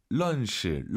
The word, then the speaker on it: 认识: 자, 알 발음 어려우시죠? 런시, 런시. 물론 알아듣잖아요, 선생님. – 是다. – 알 발음 약간 으르르 혀를 뒤로 보내면서 런시.